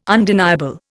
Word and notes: undeniable: Indian

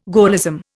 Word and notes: Gaullism: Indian

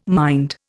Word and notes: mind: Indian

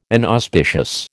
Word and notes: inauspicious: American English

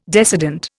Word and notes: decedent: Indian

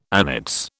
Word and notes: annates: British English

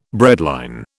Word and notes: breadline: British English

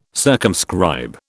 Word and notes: circumscribe: British English

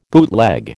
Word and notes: bootleg: American English

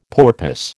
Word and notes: porpoise: American English

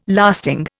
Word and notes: lasting: Indian

